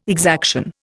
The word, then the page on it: exaction: Indian